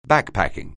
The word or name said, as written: backpacking